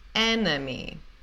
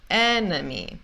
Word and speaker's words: enemy: Segunda palavra, que significa inimigo, é enemy. A primeira sílaba, a gente fala é, normal, tá? Mas a segunda e a última, porque não tem esse stress, a primeira sílaba é tônica, enemy, enemy, parece tipo como se tivesse um U, né?